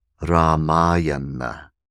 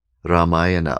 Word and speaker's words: Ramayana: And to satisfy booksellers in the West, all of whom mispronounce it, you're going to hear people saying Ramayana, the Ramayana. Ramayana always makes me laugh, but it pronounced properly Ramayana, Ramayana.